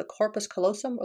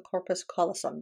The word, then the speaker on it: corpus callosum: And these two hemispheres function completely independently and are connected only by a bundle of nerves that is called the corpus callosum or the corpus callosum, depending on whether or not you put the accent on which syllable.